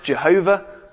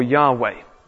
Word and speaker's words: YHWH: Okay, and it was pronounced Jehovah or Yahweh. Both pronunciations are possible.